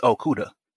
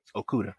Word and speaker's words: Okuda: It's Okuda. If you're concerned about the pronunciation, it's Okuda. Jeffrey Okuda. – I was very concerned. – I got you. And one of the only reasons I know about how to say his name is because he's from Grand Prairie, which of course is in the Dallas Fort Worth area.